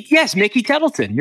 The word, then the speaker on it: Tettleton: It was he, Am I saying that right? – Oh my gosh. Yes. Wait, was it not Mickey Templeton? Wait, I have to try to remember this. Mickey Tettleton. You're right.